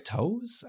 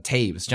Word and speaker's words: Toews: I was like Toes, uh, Toews,